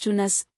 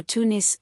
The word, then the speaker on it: Tunis: Tunis is the French transcription of a name which is pronounced Tunas, Tunas, or Tunis, with U sounding like OU in French, in Arabic.